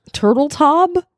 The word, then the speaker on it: Turteltaub: Turteltaub? – Turteltaub. Is that how you pronounce his name? – I think you're right.